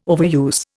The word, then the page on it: overuse: Indian